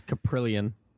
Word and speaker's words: Kaprielian: Where will James Capellian be drafted? I keep pronouncing his name wrong. I think Kaprielian, whatever. It's Kaprielian. Kaprielian, wonderful.